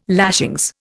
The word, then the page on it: lashings: Indian